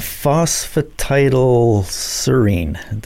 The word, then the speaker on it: phosphatidylserine: And phosphatidylserine, did I say that correctly? – Yes.